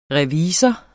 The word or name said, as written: revisor